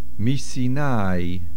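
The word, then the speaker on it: Misinai (מסיני): Misinai. The main accent is on the nun. Not Misinai, but Misinai.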